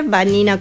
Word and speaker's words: Vanina: Sí, les dejo mis redes sociales, Vanina Jaime. Jaime, como el nombre se escribe, Vanina con B corta, oficial. Por Instagram me pueden encontrar. También en LinkedIn, Vanina Jaime,